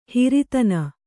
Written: ಹೆರತನ

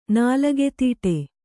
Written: ನಾಲಗೆತೀಟೆ

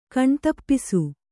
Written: ಕಣ್ತಪ್ಪಿಸು

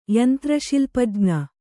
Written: ಯಂತ್ರಶಿಲ್ಪಜ್ಞ

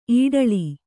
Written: ಇಟ್ಲ

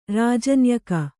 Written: ರಾಜನ್ಯಕ

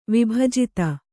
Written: ವಿಭಜಿತ

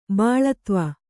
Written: ಬಾಳತ್ವ

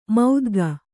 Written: ಮೌದ್ಗ

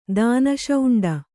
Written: ದಾನಶೌಂಡ

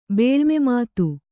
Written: ಬೇಳ್ಮೆಮಾತು

